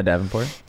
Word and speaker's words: Davenport: Um, Davenport. I say that right? – Yeah, Davenport.